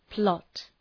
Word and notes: plot: plɒt

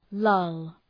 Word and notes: lull: lʌl

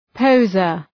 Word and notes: poseur: pəʋ’zɜ:r

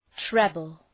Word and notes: treble: ‘trebəl

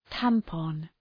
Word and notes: tampon: ‘tæmpɒn

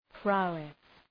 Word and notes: prowess: ‘praʋıs

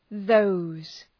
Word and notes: those: ðəʋz

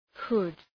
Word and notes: hood: hʋd

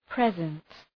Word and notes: present: prı’zent

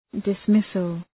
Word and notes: dismissal: dıs’mısəl

